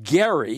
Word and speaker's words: Gerry: And across it, Stuart wrote gerrymander after Elbridge Gerry. He had a hard G, who was the governor who'd come up with this gerrymander to fix his party in power.